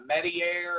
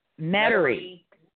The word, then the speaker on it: Metairie: The time I went, uh, Medier or something like that. Metairie. Metairie. – That's where Yeah, Metairie is Metairie is in Jefferson Parish.